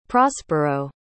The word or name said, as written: prospero